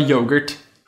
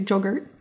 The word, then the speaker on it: yogurt: I love yogurt. – See, now you're saying it right. – I can't tell. – You can't tell. You usually say joggurt.